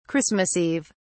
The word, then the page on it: Christmas Eve: British English